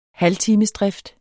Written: halvtimesdrift

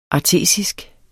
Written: artesisk

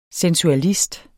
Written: sensualist

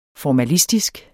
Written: formalistisk

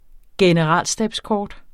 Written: generalstabskort

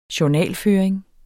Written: journal

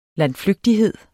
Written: landflygtighed

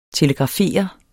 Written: telegrafere

